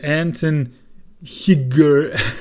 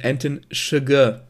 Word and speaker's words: Anton Chigurh: und zwar den Anton Higger. Äh, ich glaube, im Film wird da ausgesprochen Anton Chigurh. Chigurh. Chigurh. Okay.